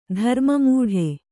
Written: ಧರ್ಮಮೂಢ

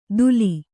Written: ಧೂಲಿ

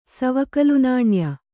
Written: ಸವಕಲುನಾಣ್ಯ

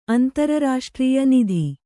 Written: ನಿಧಿ

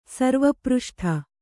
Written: ಸರ್ವಪೃಷ್ಠ

